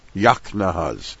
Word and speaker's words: Yaknahaz: And the abbreviation, the initial letters of each of these words, is pronounced Yaknahaz.